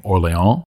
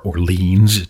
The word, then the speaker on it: Orléans: Finally, in this category of games I look forward to playing more, I'll mention the game Orléans, or Orleans, depending on how you want to pronounce it.